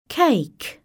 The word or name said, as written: cake